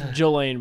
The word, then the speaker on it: Ghislaine: It's pronounced Jolaine, but it is spelled like Ghislaine.